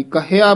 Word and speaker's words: कहया: ये हुक्म ना इस्थे नहीं रुकना, हुक्म ना कहया जाई, कहया बोलना है। कई क्या ही कह जाते हैं, जो बिल्कुल अशुद्ध उच्चारण है।